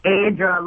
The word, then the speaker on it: Aydra: Aydra, long A. You got it.